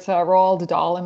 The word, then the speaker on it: Roald Dahl: And then Ben's favorite, personal favorite, Roald Dahl.